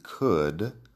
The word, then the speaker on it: could: Would y could, la L no suena.